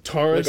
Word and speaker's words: Taurus: Taurus. Like a Ford Taurus.